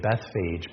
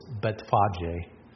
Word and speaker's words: Bethphage: And the first one, when we say it in English, we say Bethphage, but that's not actually how it's pronounced. It's Betphage. It's a Hebrew word.